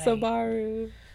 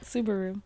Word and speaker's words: Subaru: Subaru. Yep, Subaru.